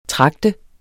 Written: tragte

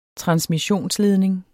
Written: transmissionsledning

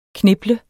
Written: kniple

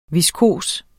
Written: viskos

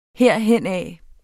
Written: herhenad

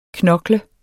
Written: knoklet

